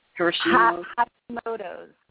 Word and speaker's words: Hashimoto's: Hashimoto's, thyroiditis.